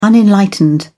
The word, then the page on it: unenlightened: British English